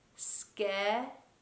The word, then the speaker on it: schedule: We pronounce it like schedule. It's a J sound. Jule. Jule.